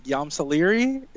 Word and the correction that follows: Ysalamiri: Is that how you say it? – Ysalamiri. – Yes, Ysalamiri. There you go.